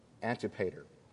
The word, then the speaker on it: Antipater: Herod the Great was the second son of, I had to look this word up and how it's pronounced, Antipater,